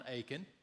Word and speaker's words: Achan: Now, his name is spelled A-C-H-A-N. It's not like the town Achan, but it's Achan.